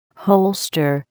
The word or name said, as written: holster